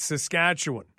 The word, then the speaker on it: Saskatchewan: And it's also Saskatchewan, not Saskatchewan. It's Saskatchewan.